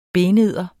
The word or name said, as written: benedder